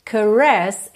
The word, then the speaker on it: caress: Atenção aqui às sílabas tônicas. Desperate é a primeira, caress é a segunda, ok?